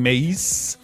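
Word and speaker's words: Mase: That is Top of the World by Brandy featuring Mace. That's how you say it. Make sure you say it like that.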